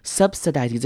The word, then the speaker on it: subsidizing: Subsidizing 这个字也要特别注意的是里面两个 I 的念法。第一个 I 呢，我们可以念成短母音 I subsidizing，或者是念轻音 subsidizing 都可以。那后面的 I 则是要念成长母音 I 的声音 subsidizing。